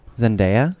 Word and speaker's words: Zendaya: Is that how you say her name? – Yeah. – Zendaya. – Yeah. – My God, I've been saying Zendaya for me too.